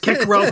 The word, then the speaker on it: Cicero: It would be pronounced Cicero correctly in Latin.